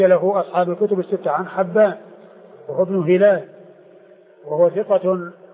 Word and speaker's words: حبان: فحبان هو الفتح الهاء وليس بكسرها، وهو ابن هلال وهو ثقة حديثه عند أصحاب الكتب الستة.